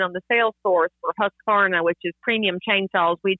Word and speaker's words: Husqvarna: Husqvarna. – You did it. It's husk like corn, varn like varnish.